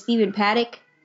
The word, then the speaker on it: Stephen Paddock: But when it comes, and I understand that in the end, they decided his name is Stephen Paddock.